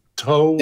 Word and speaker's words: TOW: Is it TOW or TOW? – Yeah. Either works for me.